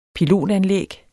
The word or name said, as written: pilotanlæg